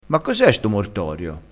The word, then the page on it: mortorio: dialettale